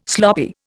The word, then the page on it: sloppy: Indian